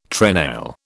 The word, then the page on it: trenail: British English